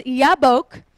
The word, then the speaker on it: Jabbok: And it says here that the name of the brook was Jabbok, or actually in Hebrew, it's pronounced Yabbok.